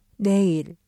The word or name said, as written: 내일